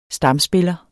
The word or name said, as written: stamspiller